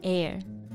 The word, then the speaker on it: heir: 对，不仅如此，她还是一千年来首位女王储。She's the House of Savoy's first female heir in a thousand years。 – 嗯，这个比我们中国这个一千年的美女听上去要厉害多了啊。 – 对，那这个 heir 这个单词的意思呢，就是继承人。Female heir 就是指的女继承人。 – 对，我们要特别注意继承人这个单词的发音。虽然它的拼写是 H E I R，但是开头的字母 H 实际上是不发音的，所以呢，不要读成了 hair，它正确的发音是 air。